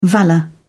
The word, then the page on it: valour: British English